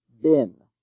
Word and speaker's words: been: Also, B-E-E-N is usually pronounced bin. Note the K-N spelling in knee, K-N-E-E.